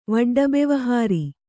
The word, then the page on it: ವಡ್ಡಬೆವಹಾರಿ: kannada